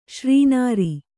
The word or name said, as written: ಶ್ರೀನಾರಿ